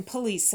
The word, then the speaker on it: police: А затем police officer тоже ударение. Police, police officer.